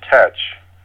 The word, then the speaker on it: Thatch: THATCH would have been more likely pronounced as tetch. Okay. And oh, coincidentally, TEACH in 1718 would have also been pronounced as tetch because the, the letters EA uh, during the transition from Middle English did not necessarily make a long vowel sound that we're familiar with today.